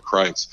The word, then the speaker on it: Kreuz: If you're ever around that area, I'd say stop off at Kreitz. And that's spelled Kreuz. It's an old Czech name. Not exactly phonetic.